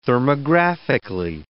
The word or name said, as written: thermographically